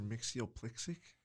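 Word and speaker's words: Mxyzptlk: Makes you a Spitalik. That's how it's pronounced in the animated cartoon. But I think what you said is how it used to be in the comics. – Mr. Mixyoplixic? – It's Mr. Look.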